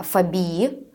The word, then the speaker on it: фобии: А имеет ли право психолог э, излечивать фобии людей? – В психиатрической среде психиатры э, делают ударение э, фобии, а не фобии.